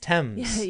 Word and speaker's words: Thames: Also from yesterday's stream, Thames is pronounced, Thames is pronounced Thames.